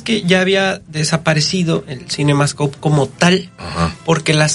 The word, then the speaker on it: CinemaScope: Sí lo pronuncié bien, ¿verdad? Sí. CinemaScope.